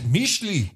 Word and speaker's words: Mixtli: We went to a wine paired dinner in San Antonio at, was it Mix? – Well, Mixley? – You pronounce it Mishli. – Mishli. – But you write it Mixtli. – It's M-I-X-T-L-I.